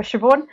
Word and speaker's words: Siobhan: My name is really weird, so it's spelled SIOBHAN for Siobhan.